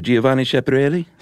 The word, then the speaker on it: Giovanni Schiaparelli: Can you say his name for everybody? – Who, Giovanni Schiaparelli? – That was good.